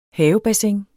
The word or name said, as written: havebassin